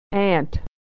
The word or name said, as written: ant